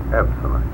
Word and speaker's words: absonite: Consider the first syllable of the word absolute and the last syllable of the word finite. If we combine abso and night, we have the word absonite.